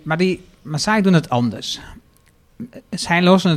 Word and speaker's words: Maasai: En het gaat over de Maasai, zeg ik het goed? – Ja, ja, zeker.